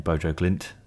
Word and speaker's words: Bodø/Glimt: I know they were linked with Knutsen, who was the coach at, I'm going to butcher the name, Bodø/Glimt in yep. Norway. – Close enough.